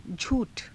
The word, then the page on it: jhuu tha: Hindi